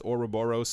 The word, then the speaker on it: Ouroboros: I thought it was Ouroboros and then Ouroboros. I learned how to say this as Ouroboros, so it will be said as Ouroboros.